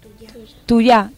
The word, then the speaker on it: tuja: Dice tuja y se pronuncia tuya. – Tuya, tuya. La Y en vez de ye es ye.